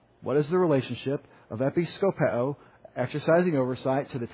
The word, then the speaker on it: episkopeo: And it's the word for episkopeo. Episkopeo. E-P-I-S-K-O-P-E-O. Long O. Episkopeo.